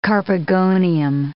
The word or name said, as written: carpogonium